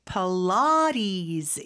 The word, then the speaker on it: Pilates: 필라테스라고 하면은 사실 원어민들은 못 알아들을 수 있을 것 같아요. Yeah. Pilates 이렇게 발음을 합니다.